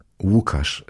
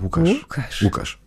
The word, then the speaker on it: Łukasz: Wie wird denn das L mit diesem Strich ausgesprochen? – Wie wow. Łukasz ist eigentlich mein Name. Das ist auch das SZ am Ende. Ist L, wie L-U-K-A-S-Z. Łukasz.